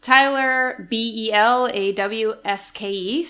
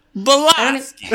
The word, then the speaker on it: Belawske: So it's Tyler Belawske. So I think it helps if you say it in like a Bostonian accent, you know? Belowski!